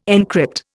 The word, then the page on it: encrypt: Indian